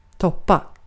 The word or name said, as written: toppar